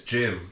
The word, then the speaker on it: Jim: Because I say my name is Jim, and people go, Jim? – No one ever questions the way you say your name. – No, my Jim has a little like, lisp on it.